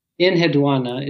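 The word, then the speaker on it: Enheduanna: Is it Eneduanna? Is that how you pronounce it? – Eneduanna is typically how it's pronounced, but as with most things in ancient languages, it's sort of an approximation.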